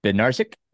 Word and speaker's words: Bednarzik: I have with me Andrew Bednarzik.